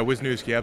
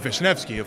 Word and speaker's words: Wisniewski: Wisniewski, right? – Yeah, Wisniewski, absolutely. Unless you want the Polish pronunciation, which is Wisniewski, of course.